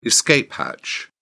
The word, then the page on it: escape hatch: British English